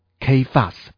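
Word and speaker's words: Kephas (Κηφᾶς): 基法我刚才说过它是一个亚兰文，读作 Kephas。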